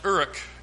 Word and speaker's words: Uruk: It is pronounced Uruk, I believe, in the Akkadian language.